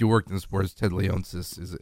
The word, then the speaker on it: Leonsis: So, I might butcher this name. Ted Leonsis? Do you know him? – Leonsis. – Yeah.